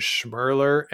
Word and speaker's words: Schmirler: So after Vic, I have Sandra Schmirler. I think that team just captured everybody's hearts in 1996, 7, and 8. The mm-hmm. fact that her name was Schmirler and it rhymes with curler, really just the media loved that.